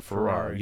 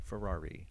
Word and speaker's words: Ferrari: Your next show that I was on, I think it was with Christina Ferrari. Yeah, I remember that one too. I pronounced her name wrong too. I thought it was Ferrar. – Ferrari, like the car.